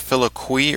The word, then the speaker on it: filioque: And when we're speaking of this controversy, it's referred to, isn't that the filioque or filioque? How do you pronounce that? – Filioque. – Filioque? – Uh-huh.